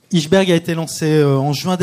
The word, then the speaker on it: Ijsberg: Tu es directeur de la publication et cofondateur d'Iceberg, d'Ichsberg, ça s'écrit I-J-S-B-E-R-G, Iceberg Magazine, comment vous dites ? – C'est une prononciation libre, c'est ce qu'on a voulu, c'est que chacun puisse approprier le nom et puisse le prononcer comme il le souhaite.